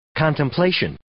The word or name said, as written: contemplation